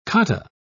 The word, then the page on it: cotta: British English